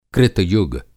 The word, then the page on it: कृतयुग: Krita Yuga